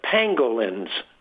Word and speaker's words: pangolins: It's not exactly clear uh, what the intermediary is, but the evidence points toward pangolins. Not let me pronounce that. Not penguins, but but pangolins, which sounds like penguins, but pangolins are one of the most uh, abused animals on the planet, yeah.